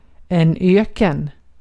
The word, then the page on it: öken: /ˈøkːən/